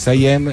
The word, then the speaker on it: Siam: Summer in Siam. – Siam, scusami. – Che ci hanno appena rimproverato che pronunciamo male in inglese.